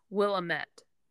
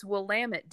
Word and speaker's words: Willamette: So the way it's spelled, you would say it as Willamette, mm-hmm. – Willamette Valley. But everybody, the vineyards around there will all say, it's Willamette, damn it.